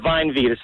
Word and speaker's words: VineVida: V-I-N-E-V-I-D-A. – Vine Vida? – Yeah, Vine Vida. It's pronounced Vine Vida.